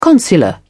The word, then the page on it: consular: British English